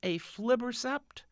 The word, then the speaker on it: aflibercept: Eylea has a generic name, and it's pronounced aflibercept.